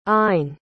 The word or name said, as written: eyen